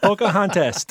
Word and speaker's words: Pocahontas: What did you rhyme with pompous? Contest? – Pompous and contest. Pocahontas.